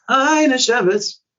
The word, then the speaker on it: Hajnasiewicz: Hajnasiewicz.